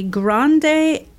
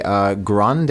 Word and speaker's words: grande: So right away, grande, this is the size. It's not pronounced grand or grand. – Right. We say that E at the end of it.